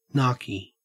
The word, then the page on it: narky: Australian English